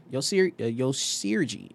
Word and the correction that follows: Orji: Is that pronounced? – Orgy. – Yvonne Orgy. Yes.